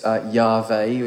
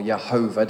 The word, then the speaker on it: Yahweh: It's obviously, it's Yahweh, which how it, or Yehovah, depending how you want to pronounce it. It's Y-H-V-H, not Y-H-W-H, that's as it is in the Hebrew. Yud-Heh-Vav-Heh. So Yehovah or Yahweh, we don't really know how it's pronounced.